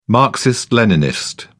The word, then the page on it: marxist-leninist: British English